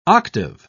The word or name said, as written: octave